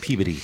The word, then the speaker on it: Peabody: Like a suburb of Boston, and it's pronounced Peabody.